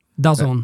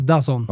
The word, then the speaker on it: DAZN: Dazon. Eh, Dazon, ja fast det, ja, det är... – Det uttalas Dazon. Ja, men bokstäverna är D-A-Z-N.